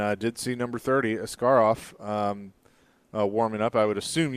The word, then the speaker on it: Askarov: Say that guy's name again. – Askarov. Yaroslav Askarov. – You sound like Tom Brokaw when you said it. Yaroslav Askarov. – Askarov. – Okay, glad you can pronounce that.